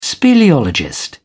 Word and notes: speleologist: British English

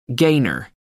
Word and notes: gainer: American English

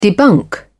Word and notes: debunk: British English

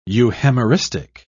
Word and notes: euhemeristic: British English

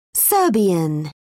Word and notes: serbian: British English

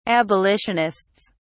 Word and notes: abolitionists: British English

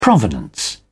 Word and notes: provenance: British English

